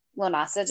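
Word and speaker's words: Lughnasadh: I will say though that so Lammas, I think a lot of people say Lammas instead of Lunasa just because it's easier to pronounce. Because Yeah. – again, it's a Celtic word, so you see it the way it's spelled, you're like, I'm not even gonna try that.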